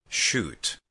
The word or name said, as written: shoot